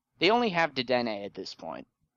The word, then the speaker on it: Dedenne: But Dedenne, right? That's how you say it.